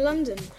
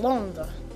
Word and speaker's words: Londres: You would say, mon père habite à Londres. – But wouldn't that be London? – No. London is one of those special words where it changes in French.